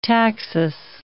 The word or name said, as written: taxus